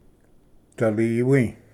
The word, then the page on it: ᏓᎵᏫ: daliwi